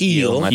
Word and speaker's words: Gil: Luis Gil. – Oh, the pitcher for the Yankees. – Oh, Yankees. – That's right. Yep. Luis Gil. – It's Gil.